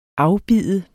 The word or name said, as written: afbide